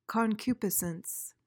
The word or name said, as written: concupiscence